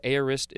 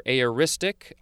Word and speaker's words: aorist: And let's check if I pronounced that correctly, because aorist is the next word.